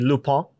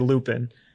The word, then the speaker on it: Lupin: By the way, we're also going to be pronouncing Lupin wrong basically the whole time because we don't speak French. It's supposed to be Lupin, but we speak English, so we say Lupin.